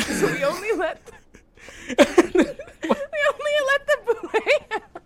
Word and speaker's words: bulette: And I'm really sorry to say this, but it's not pronounced bulette. It's not pronounced bulette. I was prepping according to the guy who made it. – What's it called? – It's called a boulet.